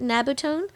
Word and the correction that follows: nabumetone: Nabumetone. – Nabumetone, okay.